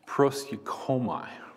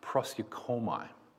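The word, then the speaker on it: proskuneo: The Greek word for pray is a word that's pronounced proskoumai. Proskoumai.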